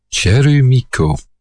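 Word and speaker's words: chelmico: group is Chelmiko. Well, in Japanese it's pronounced Cherumiko, but it's a group composed of Rachel Watashiga and Mamiko Suzuki.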